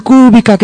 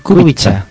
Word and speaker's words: Kubica: Cuando te digan el otro día, cuando sabes que juega ahí en la Húngaro Ring y tal, dice, el Kubica, qué piñazo. No, Kubica. Kubica, por favor, Kubica.